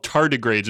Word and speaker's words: tardigrades: So taking a step back, you say these things called tardigrades. Am I saying that right? – Yeah, tardigrades. Yeah.